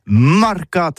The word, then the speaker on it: marcats: Mais en gascogne toulousain, c'est prononcé les marcats avec un A. Marquette. En gascogne toulousaine, bah, du côté, par exemple, de l'île des Baches, c'est l'île Jourdain. Pour parler du marché, vous prononcez les marcats.